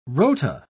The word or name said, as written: rota